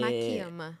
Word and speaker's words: Makiyama: Maquiama.